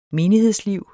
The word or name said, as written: menighedsliv